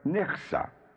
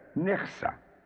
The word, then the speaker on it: Nichsa: The queen of the water elementals is known as Nixa, N-I-C-H-S-A, Nixa, Nixa.